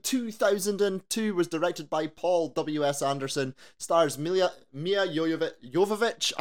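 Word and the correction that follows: Milla Jovovich: I can never say her name properly. Honestly, I practiced that so much. Mila Jovovich, Michelle Rodriguez, and James Purefoy.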